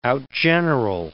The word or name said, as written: outgeneral